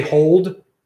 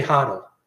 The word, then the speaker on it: HODL: I just say hold, but you say HODL. HODL, you spell it HODL, HODLbrand.ca.